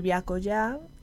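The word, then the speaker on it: Byakoya: Eh, pues nada, para quien me quiera seguir en redes o así, eh, soy Viaco ya y se escribe B-Y-A.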